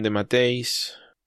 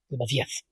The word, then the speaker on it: DeMatteis: DeMatteis. – De Matías. – De Matías, no, de Matteis. Me niego a decirle de Matías, es imposible, en ningún idioma se pronuncia así.